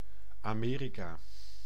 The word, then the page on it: Amerika: NL; /ɑ.ˈmeː.ri.kaː/